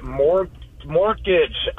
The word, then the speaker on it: mortgage: I have a problem with the word mortgage. I always say mortgage. – Oh, huh, that's interesting. – I mean, it is spelled with a silent T.